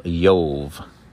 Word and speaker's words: Job: As I said before, the Hebrew does not have any J's, so it's really not the book of Job, but it's the book of Yov. Amen. And the name Yov, if you're interested, is spelled Aleph Yud Vav Vet. And we know that the second letter of the Hebrew alphabet, if it has a nikud in the middle, it becomes a B. If it does not, it is pronounced as a V. So it does not. And so the book's name is Yov.